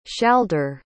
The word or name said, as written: shalder